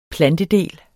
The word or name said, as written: plantedel